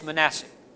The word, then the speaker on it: Manasseh: Every time I see that, I think Manassas, I think First and Second Bull Run, okay? But that's the way I pronounce it by mistake. But it's Manasseh.